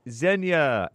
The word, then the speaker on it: Xenia: It's me, Xenia. My name is pronounced Xenia, L-O-L. I was mispronouncing it.